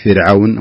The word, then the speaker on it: فرعون: كذلك في كلمة فرعون نقول فرعون، فرعون وليس فرعون، ترقق نعم في فرعون.